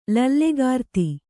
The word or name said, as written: ಲೀಲೆಗಾರ್ತಿ